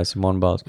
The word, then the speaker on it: Simone Biles: Am I pronouncing that correctly, Simone Biles? – I'll just assume that that's correct. Yeah. – Okay. – From everything I've been watching, I think it is pronounced Biles. – It's correct, right? Yeah, Simone Biles.